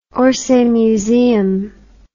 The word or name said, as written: Orsay Museum